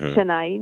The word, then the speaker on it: Chennai: miejscowości o nazwie Chennai, czy Chennai, no jakoś tak, powiedzmy, że tak to się wymawia. – Tak się mówi, tam, tak.